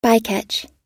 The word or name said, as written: bycatch